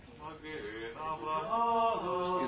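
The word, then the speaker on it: מגן אברהם: Magen Avraham.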